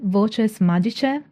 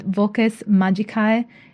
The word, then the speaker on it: voces magicae: Many spells incorporate voces magice in medieval Latin pronunciation and voces magicae in classical Latin pronunciation, which means magical words.